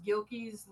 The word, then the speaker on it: Guilkey: Yeah, we're just Guilkey's Limited Adventures, and it's G U I. That silent U gets everybody, but it is Guilkey.